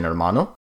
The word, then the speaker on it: hermano: First off, the H is silent, such as in hermano.